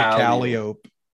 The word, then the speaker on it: Calliope: So Calliope, right? Calliope. That's Calliope. – Okay. – We pronounce it Calliope.